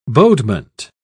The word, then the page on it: bodement: British English